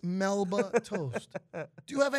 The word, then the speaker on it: Melba toast: Damn Memba toast. Why you got to say it like that, man? – Because this is what it is. – It's not. It's Melba toast.